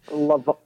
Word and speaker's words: Levant: Levant.